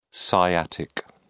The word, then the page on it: sciatic: British English